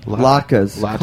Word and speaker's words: latkes: How do you pronounce it? Latke? – Latkes. Come on. Latkes.